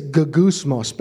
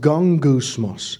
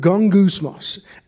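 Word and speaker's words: gongousmos (γογγυσμός): It says gogousmos, but it's pronounced gongousmos. The Greek word gongousmos.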